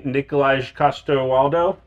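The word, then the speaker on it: Nikolaj Coster-Waldau: Nicolaj Coster-Waldau.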